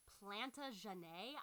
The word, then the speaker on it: Plantagenet: This family belonged to the royal house of Plantagenet.